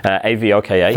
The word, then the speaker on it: Avoka: So Avoka, which you're pronouncing perfectly now, Avoka.